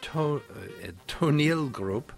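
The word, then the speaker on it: Toneelgroep: Ivo van Hove brings Amsterdam's Toneel Group.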